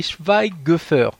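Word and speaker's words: Schweighöfer: Oui, oui parce, que, déjà, lui, là, je m'aperçois depuis tout à l'heure, j'ai fait que l'écorcher, c'est Schweigöffer. Si je me rappelle de mes cours d'allemand.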